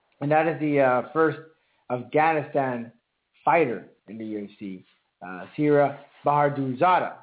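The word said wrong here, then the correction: Siyar Bahadurzada: Sir Bahadurzada.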